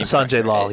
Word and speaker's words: Sanjay Lal: Sanjay Lal.